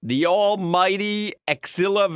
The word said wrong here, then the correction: Chilla: Valentine? Is that how you pronounce his first name? – Chilla. – Is it Chilla? – Chilla.